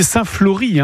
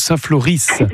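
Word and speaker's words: Saint-Floris: Donc c'est, on dit Saint-Flory, hein, on ne prononce pas le S, on est bien d'accord. – Si, on prononce bien le S. – D'accord, j'avais jamais un doute, Saint-Flory.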